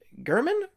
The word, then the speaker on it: German: I believe it is German Rubstoff is how it's pronounced. Is that right? – I do believe it's German, yeah. – German, that's right.